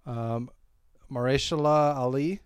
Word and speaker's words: Mahershala Ali: Directed by Barry Jenkins, starring Mahershala Ali. Is that how you pronounce it? You said it earlier. – No, yeah, that, yep, okay. That sounded right.